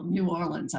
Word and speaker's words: New Orleans: New Orleans, I'm pronouncing it correctly now.